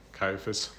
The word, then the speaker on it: Caiaphas: Caiaphas. Yep. How do you say it? – Caiaphas. The high Caiaphas.